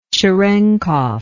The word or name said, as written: cerenkov